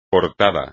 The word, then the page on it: portada: espagnol